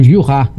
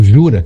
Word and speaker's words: Jura: que é o Jura, ou Jura, que se escreve, né? J-U-R-A.